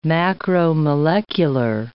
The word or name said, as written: macromolecular